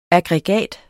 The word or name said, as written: aggregat